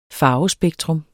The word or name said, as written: farvespektrum